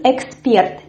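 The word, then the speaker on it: эксперт: And the word number 8 is expert. Here we also have this stress which falls on the last syllable, and we have this firm R.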